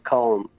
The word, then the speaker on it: Colm: Oh, when I was in England, you know, nobody could pronounce Colm. It was all Colin and Colium and Colium and all this kind of...